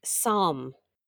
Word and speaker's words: psalm: Spelled P-S-A-R-L-M? – What? P-S-A-L-M? – Yeah, two silent letters. Psalm. Would you say the L? – Would I? Psalm. No, I would not.